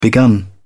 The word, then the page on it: begun: British English